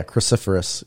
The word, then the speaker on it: cruciferous: Also not supposed to eat, how do you say this, cruciferous vegetables? – Oh, yeah, cruciferous.